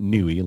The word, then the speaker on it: Yngwie: Ron Jeremy, Yngwie Malmsteen, or you can pronounce it Newey, like some people like to, right.